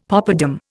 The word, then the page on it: popadam: Indian